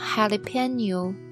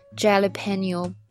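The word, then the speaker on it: jalapeño: 那么下一个呢，叫做 jalapeno poppers，jalapeno poppers 这个词呢是西班牙语是吧？所以呢，它那个 J 会读什么？会读 H 的音，所以千万不要读成 jalapeno poppers。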